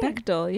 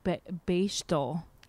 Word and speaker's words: Bechdel: it's Bechdel, right? – Bechdel, yeah. – Yeah. I think in my head, I thought it was like Bechdel or something. I had a weird pronunciation in my head for years.